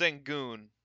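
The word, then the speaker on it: Alperen Şengün: Is it Alperen Sengun? Sengun? – It's Sengun. But I'm not exactly sure how to say his first name. Alperen. I think it's Alperen.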